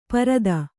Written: ಪರಿತು

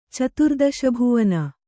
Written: ಚತುರ್ದಶಭುವನ

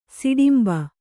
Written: ಸುಟ್ಟುಂಬೆ

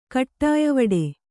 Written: ಕಟ್ಟಾಯವಡೆ

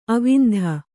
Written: ಆವಂತಿ

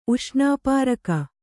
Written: ಉಷ್ಣಾಪಾರಕ

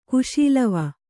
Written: ಕುಶೀಲವ

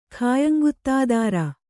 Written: ಖಾಯಂಗುತ್ತಾದಾರ

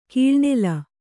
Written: ಕಿಳನಿಲ

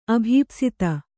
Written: ಅಭೀಪ್ಸಿತ

